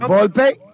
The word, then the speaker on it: Volpe: Volpe.